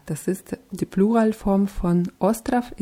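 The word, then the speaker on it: остров: Ja, wird mit zwei O's geschrieben und mit W am Ende, aber dieses W wird wie F eher ausgesprochen. Ostrov. – Ostrov. – Und das zweite O wird eher so ein bisschen verschluckt. Ostrov.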